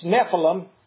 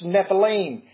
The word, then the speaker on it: Nephilim: It's Nephilim is how most people pronounce it. That's one. But if there's more than one, it's Nephilim.